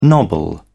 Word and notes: nobble: British English

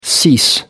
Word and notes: cease: American English

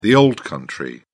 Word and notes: old country: British English

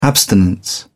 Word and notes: abstinence: British English

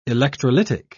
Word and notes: electrolytic: British English

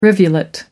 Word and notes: rivulet: American English

